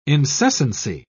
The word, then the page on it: incessancy: British English